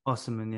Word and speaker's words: Osimhen: Osimhen, yeah.